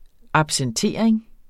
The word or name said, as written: absentering